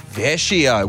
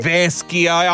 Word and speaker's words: Vecchio: Vecchio. – Oh, it's not a hard C.